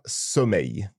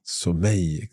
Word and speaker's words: sommeil: It's avoir sommeil. – Sommeil. It's sometimes a little tricky to pronounce that word.